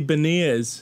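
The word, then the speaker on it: Beniers: Greg, you got to say it right. It's Matty Berniers.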